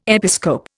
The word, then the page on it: episcope: Indian